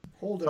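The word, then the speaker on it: Holdo: We get an introduction to a great character, Lord Den's general, how we pronounce it, Holoda? – Holder. – Somewhere in there. Holder. I'm close.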